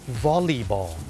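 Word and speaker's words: volleyball: แต่จริงๆแล้วนะครับเจ้าของภาษาเนี่ยไม่ได้ออกเสียงว่าวอลเลย์บอลนะครับเขาออกเสียงว่าวอลลีบอลวอลลีบอลตัว LEY เป็นออกเสียงลี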